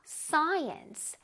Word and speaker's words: science: Science. Science. S-C-I 부분을 아주 뚜렷하게 발음해 줄 거야. S-S-S-Science. Alright? S 부분을 살짝 부드럽게 한 다음에 C-I 부분을 조금 힘줘서 Science. Science.